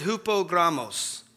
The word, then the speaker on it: hupogrammos: Because when we look at the phrase there that Peter uses, "leaving you an example," that phrase comes from a Greek word, and you pronounce it hupogrammos.